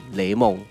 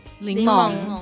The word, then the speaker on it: 柠檬: 时候的印象，所以其实有些是以前小时候听来的时候啊，自己觉得是这样发音，像是我到了很大的时候才知道那个是念雷梦，柠檬，